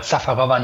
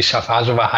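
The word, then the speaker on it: Šafářová: Safarova nennen werde, auch wenn sie Chafarjova heißt.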